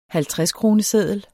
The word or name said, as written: halvtredskroneseddel